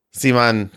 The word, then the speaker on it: CMON: Yeah, it's just C-M-O-N, all capitals. Simon sounds right. But on the Dice Tower News, I pronounce it the way that I think the way they want it to be pronounced. – Yeah. – Yeah. But this is my podcast and I can say Simon.